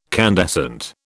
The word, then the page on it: candescent: British English